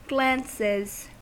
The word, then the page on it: glances: American English